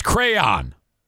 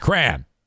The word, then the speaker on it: crayon: He says it's crayon. No, it's not. It's crayon. That is pronounced cran.